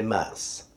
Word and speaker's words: Messe: M-E-S-S-E, how do you pronounce that? La Messe. Oh, I'm so close. It is Messe.